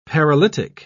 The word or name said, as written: paralytic